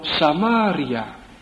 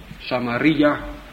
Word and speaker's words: Samaria: En staat er in onze tekst dat Jezus, deze Jood, niet waar, het geslacht van David, dat deze Jezus nu juist door Samaria, of hoe u het ook uitspreken wilt, Samaria, moest gaan.